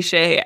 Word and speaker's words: Skjei: D-U-S-C-H-E-Y. – It's almost like a cupful situation where I always say K-K-U-P-F-L. Maybe we need to start saying Brady Shea, S-K-J-E-I. – You're right, yeah, which doesn't sound at all like how it's spelled.